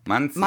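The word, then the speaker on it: Manizha: Äh, ja, wie würdest du den aussprechen? Mazia? Manzia? – Ja. Manzia.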